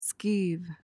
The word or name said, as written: skeeve